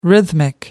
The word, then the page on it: rhythmic: American English